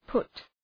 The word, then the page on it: put: pʋt